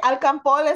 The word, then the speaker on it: alcanfor: I don't know, it's camphor. – It's camphor, people. Camphor. I love when Caribbeans always pronounce it because all of a sudden. – That's not, oh. – What? What were you gonna say? – I was gonna say alcanfor is how you say it in Spanish.